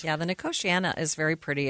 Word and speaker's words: Nicotiana: It's spelled like nicotine with an I-A-N-A at the end, but pronounced Nicotiana.